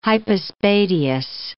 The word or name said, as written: hypospadias